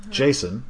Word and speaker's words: Jacen: Like, the concept of a true neutral Jedi is what Han Solo and Leia's kid, like their son, Jason, or that's how I always pronounced it. That's how... – Yeah, J-A-C-E-N, for those who...